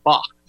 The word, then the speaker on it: Bacque: Now, Back is spelled B-A-C-Q-U-E. That's why I pronounced it Bach.